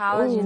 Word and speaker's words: Haaland: O Haaland, né? – É. Eu vi o nome dele sendo pronunciado na língua original dele, né? – É assim que se pronuncia.